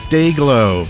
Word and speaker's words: DYGL: Prior to that, we heard Dayglow from Tokyo. That's how it's pronounced, but it's spelled D-Y-G-L, in case you're looking for it.